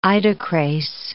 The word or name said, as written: idocrase